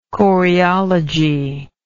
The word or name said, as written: choreologist